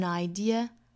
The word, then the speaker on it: idea: Have an idea, have an idea。 – Idea 这个词单独发的时候，一定注意尾部是没有这个这个发音的，是 idea, idea 它是一个元音的结尾。